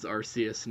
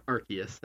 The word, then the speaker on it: Arceus: Definitely, like, Legends Arceus next year. Or Arceus, I should say. I got to get used to pronouncing it.